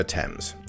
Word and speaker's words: Thames: And the correct pronunciation of Yorkshire is Yorkshire, Chiswick is still Chiswick, and the Thames is, of course, the River Thames.